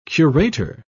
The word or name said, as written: curator